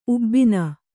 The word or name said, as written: ಉಬ್ಬಿನ